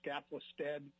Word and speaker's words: Skaflestad: This is Kenny Skaflestad. Last name is spelled S-K-A-F-L-E-S, as in Sam, T-A-D.